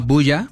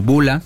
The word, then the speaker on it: Bulla: Y bueno, tenemos otra recomendación que igual no sé si la conozcas, se llama Bulla, algunos lo pronuncian como Bula, tal cual.